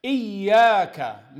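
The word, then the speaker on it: إياك: Iyyaka, meaning we worship you, O Allah. So he needs to show the stress on the ya.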